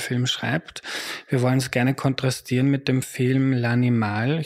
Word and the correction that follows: L'Animale: Ich hoffe, ich habe es richtig ausgesprochen. – L'Animale, na bitte falsch.